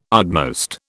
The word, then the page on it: utmost: British English